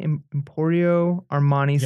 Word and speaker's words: Emporio Armani: One, picked up a St. John evening silk sheer outfit and an Empori Armani, am I saying that right? Emporio Armani yes.